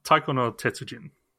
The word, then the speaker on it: Taiko no Tetsujin: I know, at least know how to pronounce it. Taiko no Tetsujin.